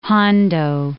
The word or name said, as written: Hondo